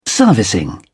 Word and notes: servicing: British English

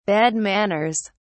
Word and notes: bad manners: British English

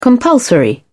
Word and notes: compulsory: British English

